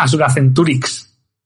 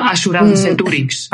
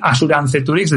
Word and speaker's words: Asuranceturix: En vez de decir Asuranceturix decía Asuracenturix porque me sonaba mucho más fácil de decir, no sé.